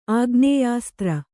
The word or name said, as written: ಆಗ್ನೇಯಾಸ್ತ್ರ